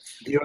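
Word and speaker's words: Vue: View account, View.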